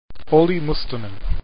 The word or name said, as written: Mustonen